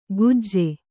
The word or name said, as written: ಕುಜೆ